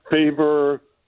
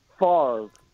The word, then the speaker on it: Favre: And I didn't even know how to pronounce his name. Favor, you know, Far, whatever. And Hub says, hey, there's a guy on the Falcons that just got traded from Southern Mississippi to Green Bay. I said, I looked at this, Favre, and I'm trying to think, okay, how do you pronounce that? And he goes, Brett Favre.